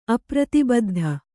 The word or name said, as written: ಅಪ್ರತಿಬದ್ಧ